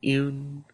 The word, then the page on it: iun: Lwaitel